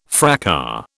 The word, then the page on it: fracas: British English